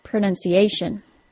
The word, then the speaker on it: pronunciation: Words that include T-I-O-N, as well as S-I-O-N, are stressed on the syllable before the T-I-O-N. That means in the word pronunciation, it is the tiny syllable that only contains the letter A that is stressed, pronunciation.